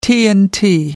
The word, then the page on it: tnt: American English